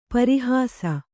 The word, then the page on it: ಪರೀಹಾಸ: parī hāsa